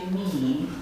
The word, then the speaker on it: mihi: Acum să știți că în latina clasică ei nu pronunțau mihi cum pronunțăm noi, ci mihi, da? Omul avea rolul de a marca hiat, nu se pronunța. Cât nu se pronunță în latină încă din perioada lui Plaut și poate de dinainte, este doar notat grafic.